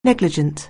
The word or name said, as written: negligent